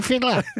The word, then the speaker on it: Fittler: Freddie Fiddler. I have a silent T in my name.